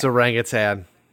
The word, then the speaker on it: orangutan: There's no G at the end? – No, the G, just people pronounce the G, but it's not really there. But it's orangutan. We were saying orangutan earlier.